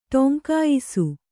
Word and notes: ಟೊಂಕಾಯಿಸು: Kannada

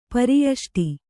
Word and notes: ಪರಿಯಷ್ಟಿ: pari yaṣṭi

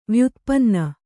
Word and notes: ವ್ಯುತ್ಪನ್ನ: vyutpanna

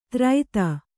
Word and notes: ತ್ರೈತ: Kannada

